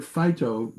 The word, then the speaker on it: phyto: It's a way, the word phyto is spelled P-H-Y-T-O, plants, what it means. but it actually is pronounced like the word fight.